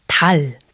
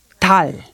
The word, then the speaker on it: 달: So pay careful attention the pronunciation of 달. – 달. – Yeah. You have to be quick with the 받침 sound.